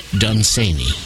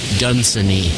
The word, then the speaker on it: Dunsany: which is incidentally how you pronounce his name. This member of the aristocracy, some pronounce it Lord Dunsany. I'm here to tell you that is quite wrong. His name rhymes with Cheney, with Dick Cheney, Lon Cheney, Lon Cheney Jr., Liz Cheney, and other strange creatures.